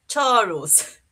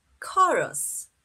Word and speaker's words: chorus: Es típico decirlo de esta manera. Chorus. Pero sí. la pronunciación correcta es chorus.